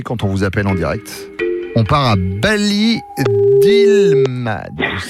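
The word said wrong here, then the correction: Barry-d'Islemade: Redonne-moi le nom de ta ville parce que je crois que je l'ai mal prononcé. – Alors, c'est pas une ville, c'est un village, c'est Bari Dilmad.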